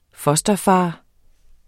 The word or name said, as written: fosterfader